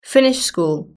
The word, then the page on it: finish school: British English